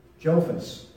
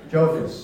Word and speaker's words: Jophus: J-O-P-H-U-S. Jophus. Jophus.